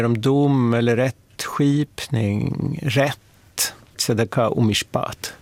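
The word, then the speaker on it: Tzedaka (צדקה): Och det viktigaste hebreiska ordet, det är tzedaka. – Tzedaka? – Ja. Anneli kanske vill uttala det lite bättre än vad jag gör.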